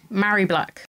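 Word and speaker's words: Mhairi Black: Murray Black. Have I pronounced that right? – Murray Black.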